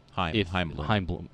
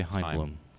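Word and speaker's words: Chaim Bloom: I saw something today that, do you think that Chain Bloom... – Heimblum. – Yeah. Heimblum. Sorry. Chain... Why do you say Heimblum? – That's how you say it. C-H-A-I-M. You say it Heim.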